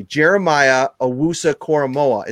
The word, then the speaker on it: Jeremiah Owusu-Koramoah: Jeremiah Owusu-Koramoah.